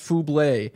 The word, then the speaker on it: Bublé: He's now correcting my pronunciation. He says not Fubbles. It's pronounced Fublé. It's French, yeah, like Michael Bublé.